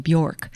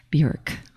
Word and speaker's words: Björk: At the very top of the set, we heard from Björk. That's apparently the pronunciation. It's hard to break the habit of saying Björk, but apparently it is Björk.